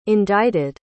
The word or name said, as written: indicted